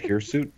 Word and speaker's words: hirsute: But that's how you say it, right? Hirsut? – Yes.